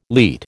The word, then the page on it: leat: American English